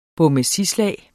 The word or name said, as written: bommesislag